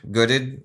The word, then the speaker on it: Goddard: Goddard. – Yeah. I can't pronounce it, but, good. It's good and Ard.